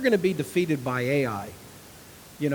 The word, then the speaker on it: Ai: Well, because of Achan's greed, the next battle that they were going to fight with a people called Ai, and that's exactly how you spell it, Ai, and that's how you pronounce it, Ai.